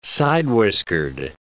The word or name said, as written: side-whiskered